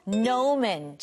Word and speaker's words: gnomon: 자, 옆에 페이지는 sundial, 자, 해시계, 그리고 gnomon. 친구들, 여기 영어로는 발음할 때는 G가 안 들려요.